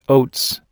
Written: oats

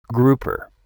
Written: grouper

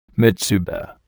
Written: mitsuba

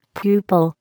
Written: pupil

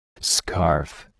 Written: scarf

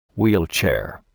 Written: wheelchair

